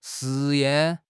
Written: 试验

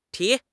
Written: ti2

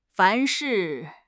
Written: 凡是

